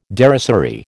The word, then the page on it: derisory: American English